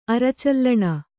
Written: ಅರಚಲ್ಲಣ

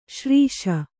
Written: ಸಿರಸ

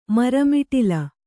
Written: ಮರಮಿಟಿಲ

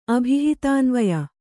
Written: ಅಭಿಹಿತಾನ್ವಯ

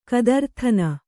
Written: ಗದರುದನಿ

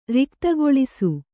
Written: ರಿಕ್ತಗೊಳಿಸು

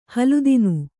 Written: ಹಲುದಿನು

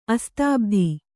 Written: ಅಸ್ತಾಬ್ಧಿ

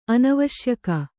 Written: ಅನಾವಶ್ಯಕ

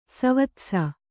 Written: ಸವತ್ಸ

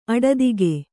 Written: ಅಡದಿಗೆ

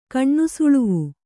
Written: ಕಣ್ಣುಸುಳುವು

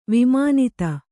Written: ವಿಮಾನಿತ

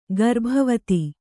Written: ಗರ್ಭವತಿ

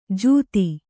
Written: ಜೂತಿ

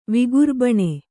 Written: ವಿಗುರ್ಬಣೆ

